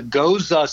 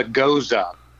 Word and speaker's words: Gose: So if you see a beer that's it says G-O-S-E on it, it's a goza style. And it's pronounced similarly to the name Rosa, and so it is a goza.